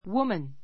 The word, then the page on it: woman: wúmən; ウマン